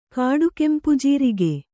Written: ಕಾಡುಕೆಂಪುಜೀರಿಗೆ

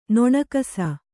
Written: ನೊಣಕಸ

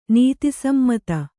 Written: ನೀತಿಸಮ್ಮತ

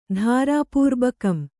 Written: ಧಾರಾಪೂರ್ಬಕಂ

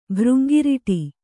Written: ಭೃಂಗಿರಿಟಿ

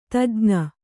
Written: ತಜ್ಞ

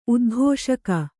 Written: ಉದ್ಘೋಷಕ

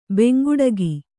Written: ಬೆಂಗುಡಗಿ

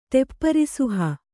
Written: ಟೆಪ್ಪರಿಸುಹ

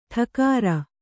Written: ಠೋಕರ

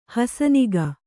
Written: ಹುಸಿನಗೆ

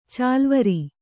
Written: ಚಾಲುವರೆ

